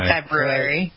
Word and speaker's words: February: February. People so misspell it and they're so sloppy about the pronunciation. Let's go to the library. – Well, I was taught that the first R is a silent R.